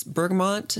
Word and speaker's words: bergamot: It's bergamot. Is that how you say it? – Mm-hmm. – It's kind of the essential ingredient, but it's like lavendery mixed with citrus.